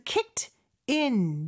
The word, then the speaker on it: kicked in: 여기서 kicked in 이 부분이 연음이 되면서 kicked in 이렇게 발음이 된다고도 말씀드렸었죠.